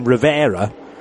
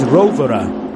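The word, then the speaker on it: Rovera: Many Italians were correcting John, and a few others as well, Martin Haven, I think, because we've always called him Rivera, but the correct Italian pronunciation is Rovere.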